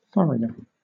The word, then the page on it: furrier: British English